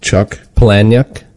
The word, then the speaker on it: Palahniuk: Chuck Palahniuk. That's the best way I've come up with pronouncing it. – Okay. – I believe it's correct.